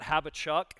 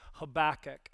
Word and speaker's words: Habakkuk: Just want to be safe, And so a lot of people, even when I would joke with my kids about this, I would call it Habachuk, just kind of like, okay, I definitely have not pronounced it right. But the best sort of way to pronounce it is Habakkuk.